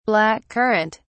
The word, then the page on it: black currant: British English